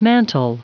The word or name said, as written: mantle